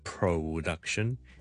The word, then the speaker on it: production: production, 虽然我 pro 我都唔会读 pro 或者 pro, 唔系 production, 或者 production again the vowel is schwa 所以 production 原因 again the same second syllable duck 先系重音，咁前面嗰个系轻音，所以我哋读唔好 pro 而系 production and offensive 就冇 offensive 啦。